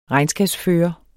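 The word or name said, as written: REGNSKABSFØRER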